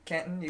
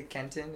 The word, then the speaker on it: Kenton: Yeah, you can say Kenton, you can say Kenton, it doesn't matter.